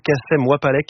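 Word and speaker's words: Kacem Wapalek: Hippocampe Fou, Yoshi, Dédi et surtout Kassem Wapalek, qui m'a mis les mots avec un brio énorme. Vous rigolez, j'ai mal prononcé? – Non, c'est bon, parfait.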